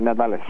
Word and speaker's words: Nadales: Yeah, Nadales.